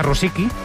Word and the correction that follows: Rusovsky: Se dice así, ¿no? – Rusovsky. – Ah, Rusovsky.